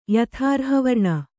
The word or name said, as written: ಯಥಾರ್ಹವರ್ಣ